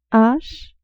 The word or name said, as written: h